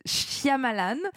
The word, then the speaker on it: Shyamalan: On continue notre tour d'horizon avec le nouveau film du réalisateur Night Shyamalan. – Bravo. Voilà, j'ai réussi à le dire.